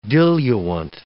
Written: diluent

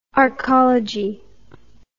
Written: arcology

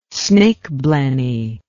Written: snakeblenny

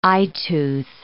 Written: eyetooth

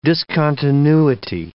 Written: discontinuity

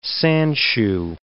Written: sandshoe

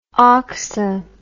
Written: oxa

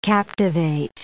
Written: captivate

